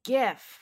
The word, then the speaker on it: GIF: Newsweek declares it's GIF with a hard G.